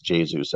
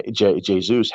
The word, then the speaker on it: Jesus: But Kevin De Bruyne, Raheem Sterling, Gabriel Jesus, it's actually pronounced Jesus, I believe.